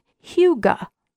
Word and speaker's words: hygge: So it looks like Holy Hygge or Hygge or something, but it is actually a Danish word. – Oh. – And it is pronounced Hygge.